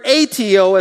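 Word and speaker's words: aiteo (αἰτέω): And so I looked up this word ask here, and it's a Greek word, aiteo is how I would pronounce it, but it's spelled A-I-T-E-O.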